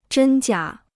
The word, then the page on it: 真假: zhēn jiǎ